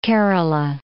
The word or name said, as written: Kerala